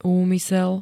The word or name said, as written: úmysel